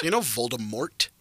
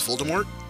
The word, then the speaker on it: Voldemort: According to this, Rowling has corrected people saying that the T at the end of his name is silent because it's supposed to be French. – So it's Voldemort? – But here's the issue.